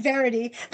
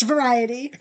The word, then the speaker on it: variety: He said verity. That's one of the words he always mispronounces. It's variety, but he said verity.